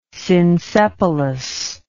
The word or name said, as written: synsepalous